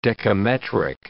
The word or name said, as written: dekametric